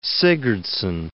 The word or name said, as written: Sigurdsson